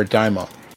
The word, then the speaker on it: daimon: They're also found in the Septuagint, which refers to evil spirits as demons or daimon. – What's with the pronunciation of daimon? Is that a thing or is it just? – Oh, it's just the Greek word. It's D A I M O N.